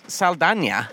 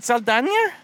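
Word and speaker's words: Saldaña: Zoe Saldana. – There's an N-Y-A over it, but I've never heard someone pronounce the N-Y-A. – Interesting, man. Yeah, I don't know it. – Does anybody know? – N-Y-A.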